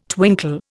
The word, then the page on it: twinkle: Indian